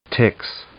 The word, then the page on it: tix: noun